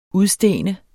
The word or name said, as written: udstene